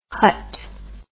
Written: Hutt

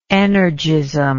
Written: energistic

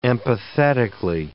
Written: empathetically

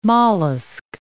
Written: mollusc

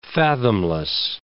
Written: fathomless

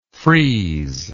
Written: freezability

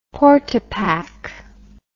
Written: portapak

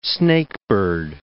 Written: snakebird

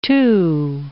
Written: too

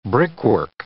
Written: brickwork

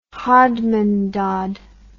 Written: hodmandod

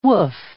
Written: woof

